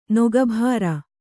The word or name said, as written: ನಗೆಬೀರು